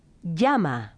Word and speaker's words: llama: Double L's are pronounced like the English Y. – Llama. Tortilla.